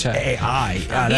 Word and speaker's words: Pichai: It is Pichai.